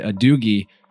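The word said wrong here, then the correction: Gadugi: but we found out we had an error in the pronunciation. It's actually gadoogie.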